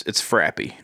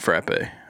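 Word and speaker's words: frappé: And another takeaway from today, it's frappy, not frappe. I've been pronouncing it wrong all this frappy.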